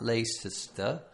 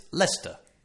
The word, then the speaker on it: Leicester: And Leicester is spelled L-E-I-C-E-S-T-E-R. – Yes. – So you might, if you didn't know how to pronounce it, you might say something like Leicester. Or something like that. Leicester. – Yes. – But it's Leicester. – Exactly. – Two syllables. Leicester.